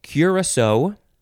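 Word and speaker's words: Curaçao: Next is, you can say Curacao, Curacao, or just Curacao or Curacao, and that's, or you can emphasize the last syllable more so, Curacao, Curacao. I always just say Curacao. C-U-R-A-C-A-O, and there is that little sort of C-shaped accent under the second C.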